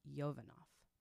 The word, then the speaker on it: Yovanoff: And this is by author Brenna Yovanoff. – Did I say that right? – Yeah.